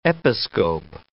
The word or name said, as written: episcope